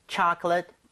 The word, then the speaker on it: chocolate: mint, chocolate, chip. 이건데, 발음까지 끝내주게 해볼까요? – 네. – Alright. chocolate 할 때는 앞에 O의 강세가 가죠. 그러면서 입 모양이 좀 벌려지겠죠. – 예. – So, 초콜릿보다는 chocolate에 가깝고요.